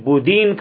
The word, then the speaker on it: بودین: نیز ہنگری کے اس اہم علاقے کو دولت عثمانی سے ملحق کر لیا اور اسے ولایت بودین کا نام دیا گیا. یعنی ترک اپنی زبان میں اسے دال سے تلفظ کرتے ہیں, بوڈا کو بودین کہا کرتے تھے.